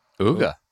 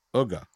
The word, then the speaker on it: Uga: The Cock Commander went after I always say it wrong. – Uga. – Uga, right? Uga.